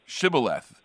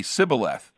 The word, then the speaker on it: Shibboleth: and they would ask them to pronounce Shibboleth, I think is what it was. And the people who were fighting against, because of their accent, they couldn't say Shibboleth. They would say Sibboleth, or vice versa.